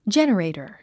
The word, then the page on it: generator: dʒénərèitər; ジェネレイタァ